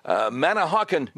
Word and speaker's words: Manahawkin: Let's go to Ryan, listening on WABC from, I hope I'm saying this right, Manahawkin, New Jersey. Did I say it right? – Correct.